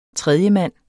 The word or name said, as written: tredjemand